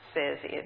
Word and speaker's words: CESI: It's pronounced CESI and typically known as CESI.